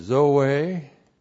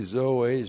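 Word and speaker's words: Zoe (ζωή): The word there for life is Zoe. Z long O long E, Zoe.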